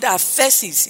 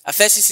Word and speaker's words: aphesis: And I've said in this series that the word remission is the word aphesis in the Greek. Aphesis is spelled A-P-H-E-S-I-S. Aphesis.